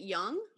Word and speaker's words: Jung: I'm sorry, did you just say Jung? – How do you say, is it Jung? – Jung? Yeah.